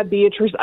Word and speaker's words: Beatrice: Like, also, why is it Beatrice and not Beatrice? – Well, I mean, that's just the way my grandmother pronounced it. – Beatrice. – So, I mean, I go by Beatrice.